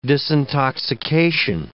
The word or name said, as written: disintoxication